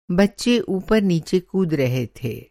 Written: ऊपर-नीचे

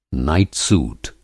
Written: नाइट-सूट

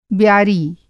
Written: ब्यारी

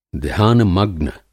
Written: ध्यानमग्न

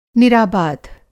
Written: निराबाध

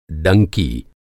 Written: डंकी

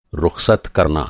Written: रुख़सत

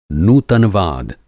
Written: नूतनवाद

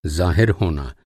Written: ज़ाहिर

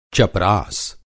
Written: चपरास

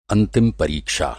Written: अंतिम

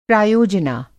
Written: प्रायोजना

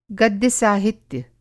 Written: गद्य साहित्य

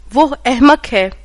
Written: एहमक़

